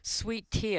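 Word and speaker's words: Sweet Thea: Sweet Tea, and that's spelled T-H-E-A.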